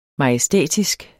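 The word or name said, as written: MAJESTÆTISK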